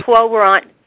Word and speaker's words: Quo Warranto: You wrote an article the other day, Quo, is it pronounced Quo Warranto? – Yeah. – Quo Warranto.